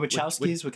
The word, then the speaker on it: Wachowskis: You said it right the first time. – That's what I was saying. I think I've been saying Kowski. Yeah, I guess it's Wachowski.